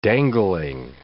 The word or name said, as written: dangling